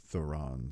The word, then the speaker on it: Theron: I always mispronounce it. – Charlize. – I'm pronouncing that part right. I think. – Yeah. I believe I've heard her say it on interviews and stuff as Theron.